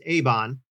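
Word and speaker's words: Eibon: It's E-I-B-O-N, but I believe it's pronounced Avon.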